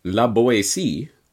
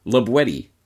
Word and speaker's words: La Boétie: Properly pronounced not, as might be thought, La Boétie, but rather La Boétie, with the hard T, as it was pronounced in the Périgord dialect of the region in which La Boétie lived.